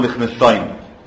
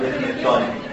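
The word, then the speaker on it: Lichtenstein: فسمى الريبير وذ مش باسمه واحد كان اسمه ليخنشتاين. مكتوب عندكم الكلمه اللي بحرف ال الكبيره قوي ديت اللي ما حدش عارف ينطقها، تتنطق ليخنشتاين، تكتبوها بالعربي لام خ نون شتاين. الكلمه القذره دي اسمها ليخنشتاين.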